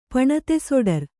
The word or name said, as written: ಪಣತೆಸೊಡರ್